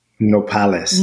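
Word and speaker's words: nopales: How do you say it again? Apollis cactus? – Nopalis. – Nopalis. Yes.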